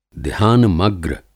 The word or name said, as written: ध्यानमग्र